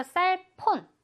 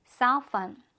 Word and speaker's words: cellphone: 이것도 마찬가지로 셀폰, 셀폰 아니고 cellphone. 한번 더. cellphone, phone이 되겠습니다. F 발음으로 잘하고 있죠?